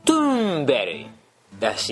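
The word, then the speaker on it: Thunberg: まあそんな感じでこの人もなかなかうまいこと発音できんかったみたいなんだけど、一番近い言い方はトゥーンベリーらしいですよ。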